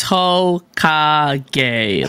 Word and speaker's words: Hokage: I guess people think that like saying ho and then gay at the end is like gonna insult me, but it's literally how you say it. It's ho ka gay. Like that's just how the name is said.